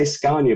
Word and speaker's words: Scania: Well, I say to them, there's no R in it. You say Scania, but there's no R in it.